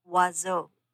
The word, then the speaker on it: oiseau: The word is pronounced oiseau.